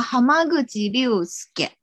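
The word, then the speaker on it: 濱口竜介: 对，日文怎么念？ – 啊，蛤蟆个吉六斯给。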